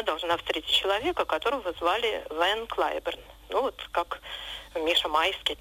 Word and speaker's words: Ван Клайберн: Его звали в английском произношении Харви Лейвен Клайберн. Ван Клайберн – это не искаженное голландское «ван», а уменьшительное от его второго имени Лейвен.